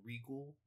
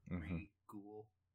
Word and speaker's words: Reghoul: And it introduces our villain of this episode, which is Regul. Which is spelled R-E-G-H-O-U-L, like Regul, which it's a good pun.